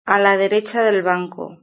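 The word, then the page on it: a la derecha del banco: European Spanish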